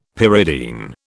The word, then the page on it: pyridine: British English